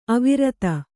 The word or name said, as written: ಅವಿರೋಧ